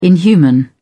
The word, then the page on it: inhuman: British English